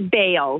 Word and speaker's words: Bale: Say your last name right? Is it Bell? – It's Bale. – Bale. – Yeah. – Okay. – B-A-A.